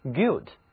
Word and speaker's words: guilt: 不过让咱们先来学习一下表示犯罪罪行的单词 guilt，罪行、罪过。这个字母 gu 合在一起呢读作哥哥的那个 g，犯了罪被抓进监狱以后呢，就会为自己的罪行而后悔内疚，于是 guilt 它又可以表示内疚， guilt，罪、犯罪、内疚。